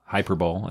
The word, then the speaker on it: Hyperbole: Hyperbole. That's actually the correct pronunciation as our intro music.